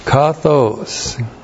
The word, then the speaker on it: kathos: This is the adverb kathos plus the demonstrative pronoun ekenos with the present active indicative of the to be verb eimi plus the adjective hagnos. Kathos. K-A-T-H long O-S. Kathos.